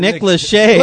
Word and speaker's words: Lachey: Nick Lachey.